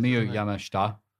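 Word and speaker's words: Miyu Yamashita: No, just for that syllable just say Shta because it sounds like a swear otherwise. – Miyu Yama Shta.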